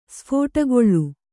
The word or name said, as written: ಸ್ಫೋಟಗೊಳ್ಳು